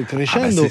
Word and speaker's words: Crescendo: Alors je le disais à Maury Vassili, on arrive sur ton aventure, ton retour aux sources avec cet album Crescendo, dont il est important de prononcer peut-être, d'avoir l'aspect italien de Crescendo.